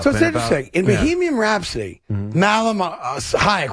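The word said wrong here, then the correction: Rami Malek: whatever his name is. Yeah. I'm mispronouncing it for sure. So he plays Freddie Mercury. – Robbie Malik. – I apologize. Thank you.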